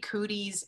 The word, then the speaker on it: Cooties: It's called Cootis. Did I say that right? – It's actually Cooties in Latin. – Cooties, excuse me.